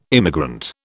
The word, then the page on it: immigrant: British English